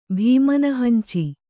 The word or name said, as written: ಭೀಮನಹಂಚಿ